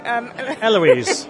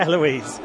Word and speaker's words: Eloise: Well, the other exciting thing, of course, Richard, is that Louise's grandfather, whose name is... – Sorry, you got my name wrong. – Eloise. Well, Eloise, Louise, I've just missed out one vowel, for goodness sake.